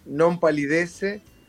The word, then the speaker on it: Nompalidece: No, no tiene ninguna pronunciación estrambólica, es como se lee, es NOMPALIDECE, es una palabra inventada,